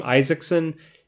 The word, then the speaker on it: Isaacson: There were a lot of times that I got called funny things, mispronounced, because of the A-A in Isaacson, I-S-A-A. So many people would say Isaacson, which I just shook my head and said, okay, close enough.